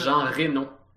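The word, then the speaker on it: Jean Reno: Ah, ça a que ça se prononce Jean Renault.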